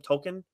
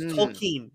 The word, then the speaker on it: Tolkien: Finally getting like correct pronunciation on his name. I think I always called him Tolkien, but it's Tolkien is how they make a whole point of it in the movie.